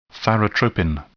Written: thyrotropin

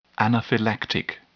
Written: anaphylactic

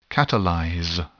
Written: catalyze

